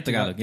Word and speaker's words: Tagalog: Yeah, man, because, I mean, I, you know, I don't speak Tagalog. Is that how you say it? – Yeah, Tagalog. Yeah.